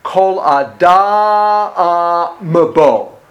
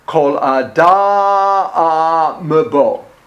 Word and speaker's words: Kol Adam Bo: Other thing, on the very last couple of syllables, on Adam Bo, you want to make M, a very dramatic, almost a separate syllable. Kol Adam Bo. Kol Adam Bo.